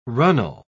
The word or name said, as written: runnel